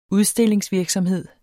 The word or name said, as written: udstillingsvirksomhed